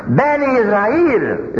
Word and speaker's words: Bani Israel: Bani Israel is the correct pronunciation in Quran.